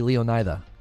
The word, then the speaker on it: Leonida: Oh, it would be Leonida. It would be I. It wouldn't be E. It'd be Leonida. And you'd pronounce the D as like a soft TH, but I don't know if that's how. I don't know.